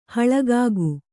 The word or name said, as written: ಹಳಗಾಗು